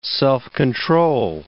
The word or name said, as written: self-control